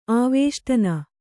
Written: ಆವೇಷ್ಟನ

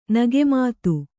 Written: ನಗೆಮಾತು

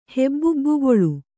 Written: ಹೆಬ್ಬಿಬ್ಬಿಗೊಳು

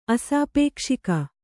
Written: ಅಸಾಪೇಕ್ಷಿಕ

